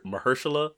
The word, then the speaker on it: Mahershala: But, so, is it Marichalla? Is that how you pronounce it? Marichalla? – Mahershala? – Mahershala. – Yeah.